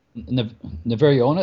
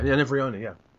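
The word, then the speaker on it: Nevèrÿon: I have also had other people compare A Stranger in Alondria to Samuel Delaney's, and I'm going to say it wrong. It's like Never Yawn. – Never Yawn. – I always say Never Yawn. Is that, you know that? – Yeah, Never Yawn, yeah.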